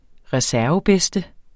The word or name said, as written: reservebedste